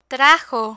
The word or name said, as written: trajo